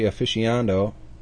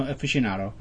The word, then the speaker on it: aficionado: He was also a technology aficionado. I said that wrong, didn't I? – No, aficionado. Yeah.